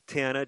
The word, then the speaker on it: Tana: So if you can remember the name Amen, of course it's pronounced Amen in this case, but A-M-E-N, and Tana, just like the end of Montana, T-A-N-A.